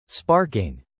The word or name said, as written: sparking